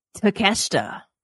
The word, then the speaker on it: Takeshita: Takeshita.